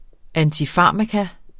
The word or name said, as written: antifarmakon